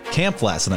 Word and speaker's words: Kampf-Lassin: Kampf-Lassin, I've learned. Been saying it wrong ever since I've known you.